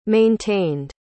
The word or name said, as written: maintained